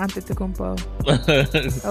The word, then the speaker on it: Antetokounmpo: I, I always been a fan of Antetokounmpo.